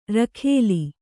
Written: ರಖೋಲೆ

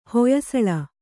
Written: ಹೊಯ್ಸಳ

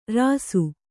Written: ರಾಸಿ

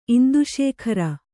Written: ಇಂದುಶೇಖರ

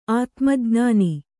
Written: ಆತ್ಮಜ್ಞಾನ

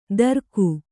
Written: ದರಕು

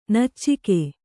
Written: ನೊಚ್ಚಗೆ

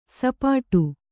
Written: ಸಪಾಟಿ

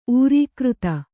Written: ಊರೀಕೃತ

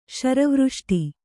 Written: ಶರವೃಷ್ಟಿ

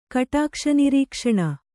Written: ಕಟಾಕ್ಷನಿರೀಕ್ಷಣ

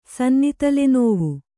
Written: ಸನ್ನಿತಲೆನೋವು

